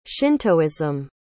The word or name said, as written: shintoism